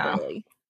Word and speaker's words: Burghley: Well, it's spelled B-U-R-G-H-L-E-Y, but I think it's still Burley.